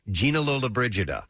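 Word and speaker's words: Gina Lollobrigida: There's odd time signatures, and one of them is 7/4 or septuplets that you can play, and you're supposed to say the name Gina Lola Brigida. – Do it. – Because it has seven syllables to it.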